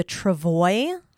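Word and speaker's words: travois: This to me, though, is interesting because it talks about the travoy, which I looked up to make sure I was saying that correctly.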